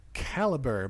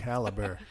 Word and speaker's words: Calibre: Caliber, but it's spelled Calibre. – In the fancy European way. – Oh. – Caliber.